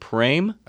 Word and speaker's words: Praim: We've got a new chocolate to talk about, a new chocolate company up on the North Shore called Praim. – That's how P-R-A-I-M, did I say it right? Okay. – Yep.